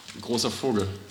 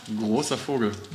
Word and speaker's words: Großer Vogel: Großer Vogel. – Huh? – Großer Vogel.